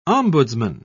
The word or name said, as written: ombudsman